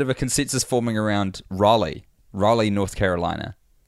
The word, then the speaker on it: Raleigh: Look, can everyone stop getting so butthurt about how I pronounce and Tim pronounces Rally, North Carolina? Rally, like a political event.